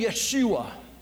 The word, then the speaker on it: Yeshua: You'll notice in the title of today's message, if you saw it in the bulletin, I use the name Yeshua, which is the Hebrew pronunciation of the English name Jesus.